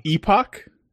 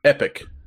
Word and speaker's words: epoch: Epoch? What's EPOC? That's like a time, right? – Yeah, I believe it is pronounced... I always say epoch, but apparently it's just pronounced epic.